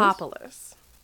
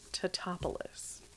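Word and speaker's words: Tatopoulos: It's Tatopolis. My name is Cammy, and it's actually pronounced Tatopolis.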